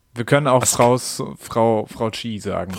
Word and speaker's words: Kyi: Ich glaube tatsächlich, der letzte Teil wird Chi ausgesprochen. Man könnte so wie AKK, man könnte ASSK machen.